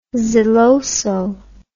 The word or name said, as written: zeloso